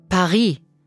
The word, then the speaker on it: Paris: Endet ein Wort mit einem Konsonanten, werden auch diese Buchstaben meistens nicht ausgesprochen. – Paris. Restaurant. Chocolat.